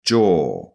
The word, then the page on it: jaw: British English